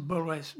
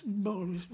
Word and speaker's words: Borges: Non, c'est Borges. – Borges. – Oui, voilà, vous le dites beaucoup mieux.